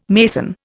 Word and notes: mason: Indian

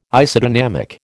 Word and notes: Isodynamic: American English

